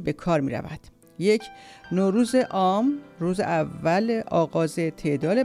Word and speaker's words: نوروز: در کشورهای مختلف تلفظ نوروز متفاوت است. در شهر لنکو هند که هر سال نوروز برگزار می شود و هفته نامه هم با نام نوروز دارد، آن را به صورت نوروز تلفظ می کنند که به تلفظ زبان دری نزدیک است.